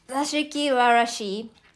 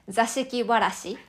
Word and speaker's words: Zashiki Warashi: Let me pronounce it right. Zashiki Warashi in Japanese, but maybe uh, people from English countries, I think they would say, uh, they would pronounce it like Zashiki Warashi or something.